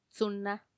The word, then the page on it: tsuna: Macuiltianguis